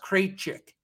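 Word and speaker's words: Krejčí: That's definitely pronounced Krejcik. K-R-E-J-C-I.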